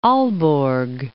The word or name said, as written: Aalborg